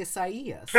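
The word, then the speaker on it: Isaías: It's just the Spanish way of pronouncing Isaiah. – Yeah. Oh, that's nice. – I think that Elon Musk's kid should change his name to Isaias.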